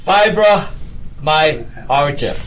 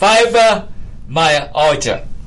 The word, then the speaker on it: fibromyalgia: Fibromyalgia. Is that correct? – That is correct. – Fibromyalgia.